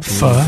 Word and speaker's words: Pho: Pho? Yeah, pho, you have to eat it all at the same time. – Pho, you have to eat it all at the same time? – Pho.